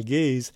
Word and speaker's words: Gees: In the next song, you'll hear the composer, Michael Gaze, as the pianist with Christoph Prégardien, the tenor. Gaze is spelled G-E-E-S.